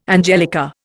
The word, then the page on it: angelica: Indian